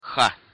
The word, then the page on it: х: Russian